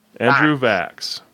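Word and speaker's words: Vachss: His last name rhymes with Tax. – Okay. Andrew Vax.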